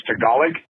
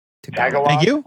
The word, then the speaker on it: Tagalog: It's pronounced Tagalog. Tagalog, our Girl Scout cookies.